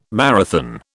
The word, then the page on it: marathon: British English